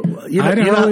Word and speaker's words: Idaho: So this was told to me by Tommy Nelson, a resident of the state of Idaho. Did I say that right? Or Idaho is correct.